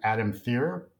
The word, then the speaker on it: Adam Thierer: Our guest today has been Adam Thier, rhymes with beer.